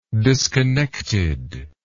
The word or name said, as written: disconnectedness